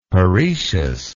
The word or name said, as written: parecious